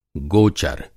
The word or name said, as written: गो-चर